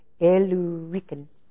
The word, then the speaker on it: Illyricum: It says Illyricum. That's how it's said to say it.